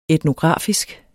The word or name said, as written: etnografisk